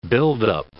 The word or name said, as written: buildup